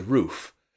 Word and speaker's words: Rufe: One of the more interesting A6M2s was a float plane version built by Nakajima and known to the Allies as the Roof, and that's spelled R-U-F-E.